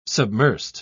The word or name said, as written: submersed